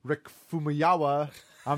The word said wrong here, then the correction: Famuyiwa: Famuyiwa. – Yeah, that guy, is leaving the project. I mean, it's disconcerting. It's disconcerting. – Famuyiwa.